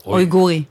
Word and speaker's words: אויגורי: אמ, האחרונה ששמעתי זה על, ה, על המיעוט, ה, איך, איך מבטאים את זה? – אויגורי. – אויגורי?